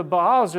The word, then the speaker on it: Baal: The second step is going after the Baals, or the gods of this world. Following the gods of this world. Now the Baals, they were, you know, Baal is the correct pronunciation of it, but he had kind of a lot of different roles.